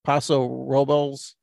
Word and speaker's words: Paso Robles: I mean, I guess I could connect it by saying, all right, well, it's from Firestone Brewery out of Paso Robles, California. Am I saying that right? – Yep. That's wine country.